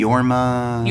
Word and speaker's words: Jorma: So anyway, this is the brainchild of Jorma Taccone. Is that how you pronounce it? – Yeah, name? – Yorma. – Yorma.